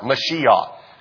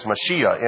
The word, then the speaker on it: Mashiach: And I said, it's Mashiach. And he said, no, it's Mashiach. You know, and something came out of his throat when he said it. So I'm not going to try to quite say it like that.